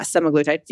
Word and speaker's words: semaglutide: Yeah, semaglutide.